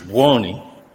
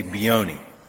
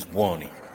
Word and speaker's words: Buoni: So properly is Buoni, but we pronounce it improperly. We say Buoni. – Buoni, okay. Because, I went to... – But if Italians would know, the right pronunciation is Buoni.